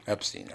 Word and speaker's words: Epstein: You pronounce it Epstein or Epstein? – Epstein. – Epstein. I just want to make sure.